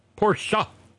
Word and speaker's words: Porsche: I should know this, because I'm the little obsessive compulsive who, whenever she hears somebody say Porsche, I cringe and yell wherever it is I am. It's a two-syllable word, Porsche.